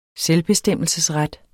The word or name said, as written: SELVBESTEMMELSE